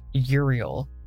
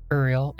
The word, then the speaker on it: Uriel: I know that he's pronounced Uriel, but I was taught Uriel, and I just want to say things the way I want to say them, so there.